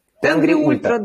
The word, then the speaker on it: Тенгри: Это будет кемп в Казахстане перед Алматинским ультрамарафоном, Теренги, как Тенгри это произносится? Тенгри Ультра он называется. – Тенгри Ультра, да.